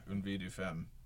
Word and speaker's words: Une vie de femme: And regarding to your recent work, how do I pronounce it? Une vie de femme? – Oh, yeah. Une vie de femme.